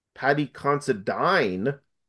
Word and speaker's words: Paddy Considine: And Tyrannosaur, directed by Paddy Considine, as he pronounces his name, I heard on the audio commentary.